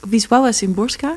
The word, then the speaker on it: Wisława Szymborska: Dat is een Poolse dichteres, Wisława Szymborska, spreek je het uit.